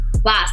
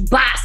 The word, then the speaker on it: Bas: Boss. The name is Boss. Don't forget it. Don't mispronounce it. It's Boss.